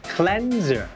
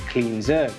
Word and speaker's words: cleanser: ครับเพราะ Cleanser แปลว่าผลิตภัณฑ์ทำความสะอาดผิวและต้องออกเสียงว่า Cleanser. ไม่ใช่ Cleanser.